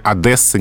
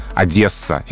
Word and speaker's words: Одесса: Ну вот словари специально отмечают, что произношение Одесса неправильно. Угу. А это слово произносится с мягким согласным Одесса и только так.